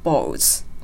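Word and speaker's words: bowls: そして発音は、ボールとボウはちょっと違うというところで、小さいウをつけるようにして発音していくのがボウズのポイントでした。そして These balls are all という部分のリンキングの練習をしていきました。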